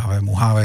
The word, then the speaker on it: Mojave: by se to podle zvuku nezdálo, tak Mojave je správná výslovnost, nebo Mojave spíše.